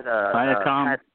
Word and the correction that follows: Siakam: Viacom, however you pronounce his name. Siakam, yeah, that's it, Siakam.